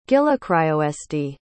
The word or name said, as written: gillecriosd